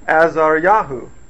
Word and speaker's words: Azariahu: But Azariahu is how you would say it in Hebrew today.